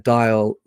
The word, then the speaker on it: Dial: Dial like the telephone or the soap.